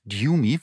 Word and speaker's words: Demi Moore: Ich bin gespannt, ob Demi Moore, die wird übrigens nicht Demi Moore ausgesprochen. Sie hat jetzt erzählt, dass sie muss das immer wieder korrigieren. Sie heißt Demi, warum auch immer. Jedenfalls Demi Moore, ob die für The Substance für einen Oscar nominiert wird.